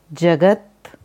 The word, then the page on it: Jagat: Sanskrit